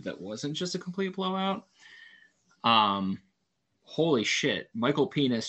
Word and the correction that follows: Penix: Jr. can throw the ball. Oh, did I say it wrong? Did I say penis? – Yeah. – Michael Penix Jr. can throw the ball.